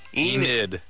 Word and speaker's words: Enid: Is that E-A-T-O-N? – Is she saying Eden or Eden? Or how do you spell it? – E-N-I-D. – E-N... – Enid. – I-D. – Old lady's name. – Enid, yeah. Yeah, we used to hunt for nutria out that way.